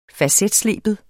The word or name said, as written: facetsleben